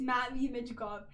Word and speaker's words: Matvei Michkov: Because we pronounced his name wrong, and I'm so sorry if you're listening to this. It's Matvei Michkov, and we we said it wrong, which we told you we probably said it wrong, but we really did say it wrong.